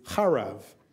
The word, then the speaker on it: harav: The word for drought is chorev, and the word for ruins is harav.